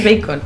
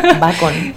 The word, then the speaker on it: Bacon: En inglés Bacon, en español Bacon.